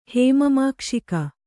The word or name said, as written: ಹೇಮಮಾಕ್ಷಿಕ